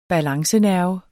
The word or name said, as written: balancenerve